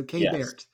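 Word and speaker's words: Keibert: So you're going to hear a lot of announcers botch that. K-Bert, whatever, you know, you hear. – Yeah. – K-Bert is K-Bert. How it's pronounced.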